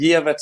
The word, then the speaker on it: Gefvert: Hur uttalar man det? Eh, ja, du. Eh, Gevert säger jag.